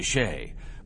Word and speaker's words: Chelly: Now, you see, Ryan, that was called Canyon de Shea, but Shea was spelled C-H-E-L-L-Y, and as you can certainly hear from my pronunciation, it sounded as though it were spelled S-H-A-Y, Shea, exactly like that name there. I just wonder. – Wonder away, Doc. You lost me at C-H-E.